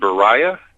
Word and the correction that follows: Briah: Slightly off on the pronunciations, but I'm not going to, hold it to that, level of specificity. I would say if I were to give the correct pronunciations as closely as I know them to be, it's Asiyah, Yetzirah, Briah, and Atzilut.